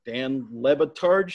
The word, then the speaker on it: Le Batard: Roy, obviously from the ESPN radio, the Dan Le Batard show. Is that how it's pronounced? – That's right.